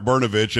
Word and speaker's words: Brnovich: It is Mark Brnovich, and it's easy to pronounce once you get it. But it is spelled. You're missing, a vowel in there somewhere. But well, it's Brnovich.